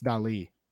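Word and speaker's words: Dalí: It's The eye actually has an accent over it, Toby, so it's Dali.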